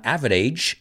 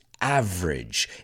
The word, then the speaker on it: average: And a recent Oxford University study found that the average European banknote, remember the pronunciation is not average, it's average. It's two syllables.